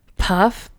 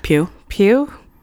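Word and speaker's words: Pugh: It's P-U-G-H. – Pew. – Pew? Is that really how you say it? Yeah. – Why is it pew? – G-H. Like cough. Puff.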